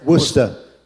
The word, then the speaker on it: Worcester: And then not only do you have the church in Makassar, and then you have the school, but then in Worcester. Did I say that right? – You're right. – Worcester. Did I hit it? – Worcester. – Worcester. – Yeah. – Yeah, Worcester.